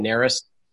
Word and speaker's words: Narus: My name is Ryan Narus. Yeah, it's spelled N-A-R-U-S.